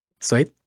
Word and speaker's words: Suede: eh, Pulp y Sud o Suede, no, aquí me corriges la pronunciación. Suede.